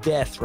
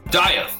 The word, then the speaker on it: Dieth: I believe it's pronounced Death, right? – Dieth, yeah, Dieth. – Oh, Dieth. All right, yeah, cool. Yeah, I've been pronouncing Dieth it wrong the whole time. – Dieth, yes. Exactly. Put the I in Death.